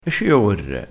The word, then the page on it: e ci orre: dialettale